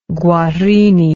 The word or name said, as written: guarini